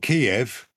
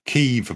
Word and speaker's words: Kyiv: They'll sober up in Kyiv, not Kiev, Kyiv, apparently.